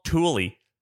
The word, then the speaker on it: Thule: Dude, that's like worse than the time that someone was like, it's called Thule. I'm like, it's spelled like Thule. So I think it's Thule. – Yeah, like don't, the Swedish, they got a different word for everything. I tell you, they should don't spell it like it rhymes with mule. If you're gonna pronounce it Thule.